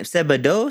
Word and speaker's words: Sebadoh: Sebadoh, Sebadoh, Sebadoh.